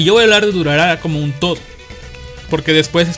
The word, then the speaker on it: Durarara: Durarara, un anime basado en la novela ligera de Narita Ryogo.